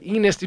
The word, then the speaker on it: Ennis: That's how it's pronounced, though. So you probably would play with it, huh? – I mean, if his last name was like Anus, you know, it would be more, but Ennis, you'd have still have to take some liberties.